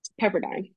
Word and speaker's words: Pepperdine: Is it Pepperdine or Pepperdine? – Pepperdine.